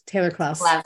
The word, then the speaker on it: Taylor-Klaus: Klaus, Taylor-Klaus.